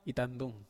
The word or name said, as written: tandung